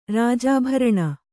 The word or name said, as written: ರಾಜಾಭರಣ